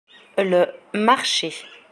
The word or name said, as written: le marche